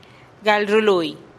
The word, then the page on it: gal rului: TdVZ